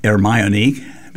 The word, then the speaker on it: Ermione: I'll call it Ermione because it is synonymous with how we say Hermione.